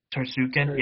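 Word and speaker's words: Tsarukyan: But we got Beneil Darnoush, and we got Arman with a really long – do you guys know how to pronounce his last name by chance? – Tarsukin, I believe. – Tarsukin, yeah.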